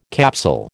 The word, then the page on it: capsule: American English